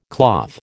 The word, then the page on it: cloth: American English